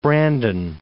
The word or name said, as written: Brandon